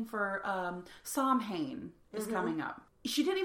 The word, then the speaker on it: Samhain: And I looked it up later and I'm like, what are these fucking words that because it's spelled Samhain. – It's Samhain, yeah.